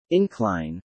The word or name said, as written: incline